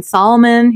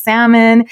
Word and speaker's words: salmon: And he was like, oh my God, I've always said salmon wrong. I'm like saying Solomon. He's like, it's salmon.